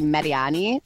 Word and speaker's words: Mariani: You know what's funny is that I always say Melani Mariani, but in Italian, it's actually Mariani.